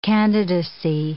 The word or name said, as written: candidacy